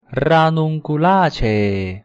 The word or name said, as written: ranunculaceae